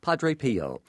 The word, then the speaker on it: Padre Pio: You talk about Padre Pio. – Padre Pio.